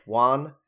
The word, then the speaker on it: wan: I also think, like, I think that they were trying to make him look more wan. Is that the way you pronounce that word? – Yes. – Wan? – No, you're right. – Wan.